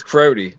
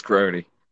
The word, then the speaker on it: Crotti: It looks like it says Crotty, but it's Crody. It's Italian last name.